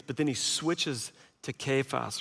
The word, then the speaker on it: Cephas: And then he changes his name mid-paragraph and says Cephas or Cephas, as it would be pronounced in Aramaic.